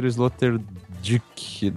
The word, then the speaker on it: Sloterdijk: Sloterdijk. Eu vi que a pronúncia certa é Sloterdijk.